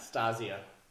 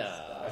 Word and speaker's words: Stazio: Is that how you pronounce it? – Yeah, it's, yeah, Stasio. – No. – Stasio. S-T-A-Z-I-O. – Oh, yeah. It's not a double Z? – Nope.